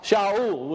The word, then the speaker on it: Shaul: When Saul of Tarsus was on his way to Damascus, of course we know him as Paul, but it's Saul. Shaul would be the correct way to say his name.